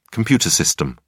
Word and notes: computer system: British English

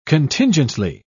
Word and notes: contingently: British English

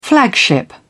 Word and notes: flagship: British English

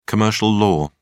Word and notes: commercial law: British English

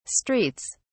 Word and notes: streets: British English

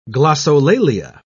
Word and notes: glossolalia: British English

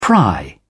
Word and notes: pry: British English

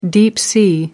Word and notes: deep sea: American English

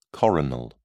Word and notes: coronal: British English

